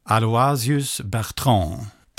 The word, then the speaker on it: Aloysius Bertrand: Bygger på tre texter ur den franska poeten Aloysius Bertrand.